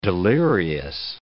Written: delirious

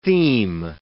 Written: theme

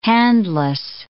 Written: handless

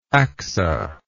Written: Achsah

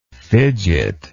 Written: fidgetingly

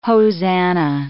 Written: hosannah

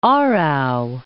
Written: Aarau

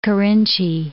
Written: Kerintji

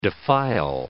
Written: defile

